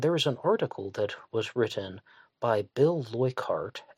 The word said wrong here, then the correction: Leukhardt: Leuchardt. Well, I butchered that one. Leukhardt.